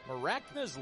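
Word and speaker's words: Mracna: So it's pronounced Maracna, but it's spelled M-R-A-C-N-A. 2-1. Outside, 3-1. So no vowel needed on the front end.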